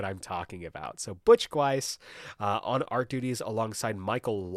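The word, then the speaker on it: Guice: This past week, I learned that his last name is pronounced Gweiss, unless I got incredibly bad info.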